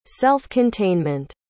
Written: self-containment

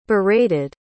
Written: berated

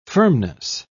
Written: firmness

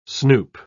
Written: snoop

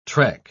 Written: trek

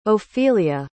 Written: ophelia

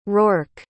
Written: roarke

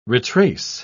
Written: retrace

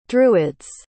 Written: druids

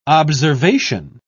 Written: observation

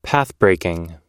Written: pathbreaking

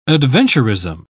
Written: adventurism

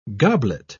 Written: goblet

